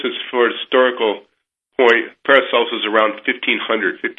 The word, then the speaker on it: Paracelsus: Did I pronounce that right? Yeah. – Yeah, that's correct. Paracelsus.